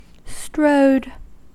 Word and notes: strode: American English

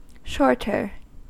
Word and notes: shorter: American English

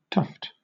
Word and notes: tuft: British English

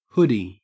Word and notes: hoodie: Australian English

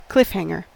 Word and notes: cliffhanger: American English

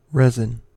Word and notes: resin: American English

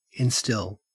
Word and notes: instill: Australian English

